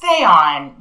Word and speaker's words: Theon: Yeah, it's pronounced Theon, but it looks in writing like Theon. – Like Game of Thrones. – Like, theme. Yes, it always makes...